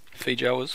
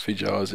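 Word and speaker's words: feijoa: how do you say that word? Fijoas? – Fijoas, yeah.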